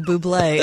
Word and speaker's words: Bublé: Bublia. Okay. What, isn't there a trumpeter named Michael Bublia? – No, that's Michael Bublé, and he's not a trumpet player.